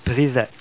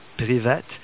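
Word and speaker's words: privet (привет): All right, the most simple greeting that you could use in the Russian language, the most basic informal way to greet someone is pronounced privet. You say privet.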